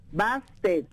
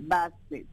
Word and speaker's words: Bastet: Bastet. B-A-S-T-E-T, Bassett.